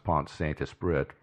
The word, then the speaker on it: Pont-Saint-Esprit: That is, it actually should be pronounced Pont-Saint-Esprit, but French are... famous for mispronouncing everything.